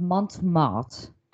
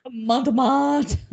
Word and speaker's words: Montmartre: Okay, so we'll start with the Monster of Montmartre. Did I say that right? – Oui. – Oui. Okay, so he had a few nicknames. Monster of Montmartre. Montmartre.